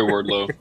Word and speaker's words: Wardlow: Lardlow. – Okay, anyways. Sorry, Wardlow.